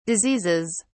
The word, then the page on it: diseases: British English